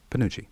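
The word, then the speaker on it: Pinocci: Mrs. Panucci. – Let's spell it. It's P-I-N-O-C-C-I. – Panucci. – I know. It wouldn't seem like that you'd pronounce it that way, but...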